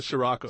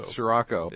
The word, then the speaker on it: Scirocco: A Scirocco. – Scirocco.